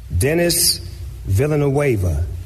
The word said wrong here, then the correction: Villeneuve: Now, in fairness, you've pronounced it wrong. Steve, if you were to pronounce this just looking at it, because it's Villeneuve, right? – It's Villeneuve. – Yeah, Villeneuve. – Denny Villeneuve, yeah.